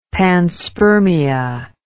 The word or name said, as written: panspermia